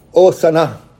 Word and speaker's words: Hosanna: And there's some words in the Greek that the H is silent, and it is for this one. And so in the Greek, it is Hosanna.